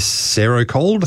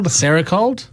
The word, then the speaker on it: Serocold: How do you spell that? – S-E-R-O-C-O-L-D. So I guess Sericold? – Sericold? I don't know. – Yeah. – Let's go with that.